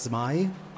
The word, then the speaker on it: Smai: Alle Leser, die sich jemals gefragt haben, wie wird denn dieser Titel ausgesprochen? Es heißt Smai, aber jeder Leser ist natürlich frei, das anders auszusprechen.